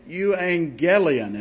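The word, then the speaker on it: euangelion (εὐαγγέλιον): What is a euangelion? And that's how it's pronounced. That doesn't have an N in that word, but it is pronounced as if it does. It's a Greek word. A euangelion, like no other.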